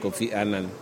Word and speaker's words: Kofi Annan: And everything is pronounced the way the big man's name is pronounced, Kofi Annan.